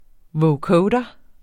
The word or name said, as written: vocoder